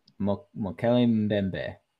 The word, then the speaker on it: Mokele-Mbembe: Oh, listeners, that is Mokele-Mbembe. – Yes. – Mokeli Mbembe.